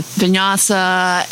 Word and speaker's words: Vinyasa: and tell us a little bit about that and all your certifications with Vinyasa, and I pronounced it correctly. – You did.